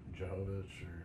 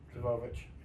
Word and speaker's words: Jovovich: The fact that it's got Mila Jovovich or Jovovich.